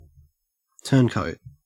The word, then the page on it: turncoat: Australian English